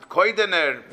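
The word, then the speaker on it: Kaidaner: His name was Rabbi Yaakov Kadaner. That's what his name was. And I think they pronounced it Koidaner, but that's the spelling.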